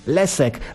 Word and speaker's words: Leszek: Bo Leszek po węgiersku czytamy jak Lesek, bo wiadomo, że po węgiersku szy to czyta się jak sy, a sy jak szy.